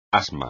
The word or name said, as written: asma